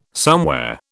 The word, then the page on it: somewhere: British English